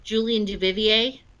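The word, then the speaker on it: Julien Duvivier: Duvivier? – Yeah, what was it? – Julian Duvivier? – Yes, okay.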